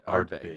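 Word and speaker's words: Ardbeg: or do you want to go with the Artbeg? How do you say that? – It's you're the boss. You said it exactly right. Artbeg, yeah, from LA.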